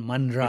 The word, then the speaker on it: Manral: Not exactly. It's Vishwas and Manral.